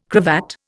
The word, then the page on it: cravat: Indian